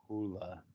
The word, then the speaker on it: Jaula: Bahula.